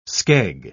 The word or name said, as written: skeg